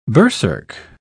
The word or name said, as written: berserk